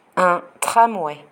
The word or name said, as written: un tramway